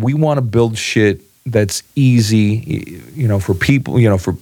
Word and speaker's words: Schiit: It's spelled S-C-H-I-I-T. It's shit. It's pronounced shit audio.